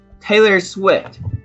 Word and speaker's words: Taylor Swift: Taylor Taylor Swift Taylor Swift 这个是什么意思呢？